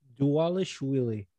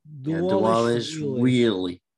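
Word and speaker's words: Dvalishvili: How do you say his last name again? – Dualishvili. – When he was. – It's like you're trying to swallow the L. – Dualishvili.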